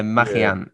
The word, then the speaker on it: Magellan: Yeah, but it's definitely not an L sound. Fernand de Magellan. Yeah. – That's right. – So Magellan, that's obviously a blatant mispronunciation of the Spanish double L.